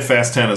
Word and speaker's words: Fast X: I think it's pronounced 10. – Yeah. Well, it's in Rome. It is. – When in Rome pronounce it 10. – Yeah. I had Fast 10 as well.